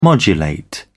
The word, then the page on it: modulate: British English